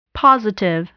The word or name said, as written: positive